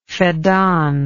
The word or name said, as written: feddan